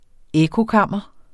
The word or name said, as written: ekkokammer